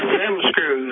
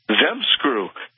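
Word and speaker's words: ZZEM Screw: You're in the South. I'm sure you're comfortable saying Dem Screw, but this is Zem Screw. Z-Z-E-M. – Zem Screw.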